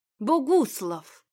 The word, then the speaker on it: Богуслав: «Я и сейчас все еще в твоем вкусе, Богуслав!» Спроси сейчас Арину кто-нибудь, почему она произнесла его имя, сделав ударение на «у», она бы не ответила.